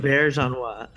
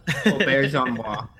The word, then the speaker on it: Auberjonois: Yeah, I saw a couple of like Odo autographs where René Auberjonois, whatever, I can't even pronounce his name. – It's Auberjonois. – Auberjonois.